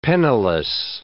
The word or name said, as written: penniless